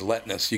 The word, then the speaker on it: Letnes: I love Stephen Letness, too, because his last name is spelled L-E-T-N-E-S. He goes, Letness. You can't forget it because it's just basically Nestle all scrambled up.